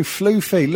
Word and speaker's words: floofy: No, floofy. Look, F L O O F Y. That's fucking floofy, all right? – That's definitely floofy. That's not fluffy.